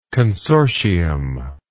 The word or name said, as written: consortium